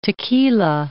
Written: tequila

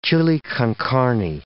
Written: chili con carne

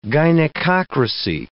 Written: gynecocracy

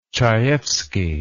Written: chayefsky

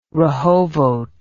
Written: rehovot

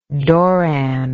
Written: doran

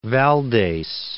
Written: Valdes

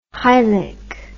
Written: hylic

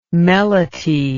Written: melete

